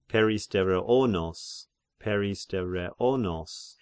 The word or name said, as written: Peristereonos